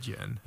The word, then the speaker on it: Jinn: It's actually spelled with a D. It's like Djinn. – Jinn. – But you say it Jinn.